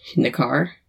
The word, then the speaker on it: Hnikar: H-J-A-L-D-R became hold. – Okay. – So it's like Hjald. Hjaldr. – Okay. – And then H-N-I-K-A-R. Nakar. Something like that.